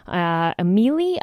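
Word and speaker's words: Amilli: So we're going to be listening to Amili, I believe is how it's pronounced. It's A-M-I-L-L-I.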